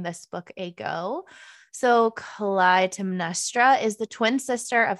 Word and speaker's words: Clytemnestra: It's Clytemnestra by Costanza Casati.